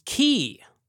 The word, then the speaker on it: Cay: By the way, usually pronounced Key, C-A-Y, Key, like the Florida Keys, but sometimes spelled K.